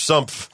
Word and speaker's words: sumph: S-U-M-P-H. Sumpf.